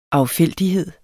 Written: affældighed

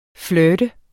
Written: FLIRTEDES

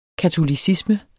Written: katolicisme

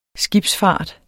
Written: skibsfart

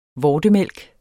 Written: vortemælk

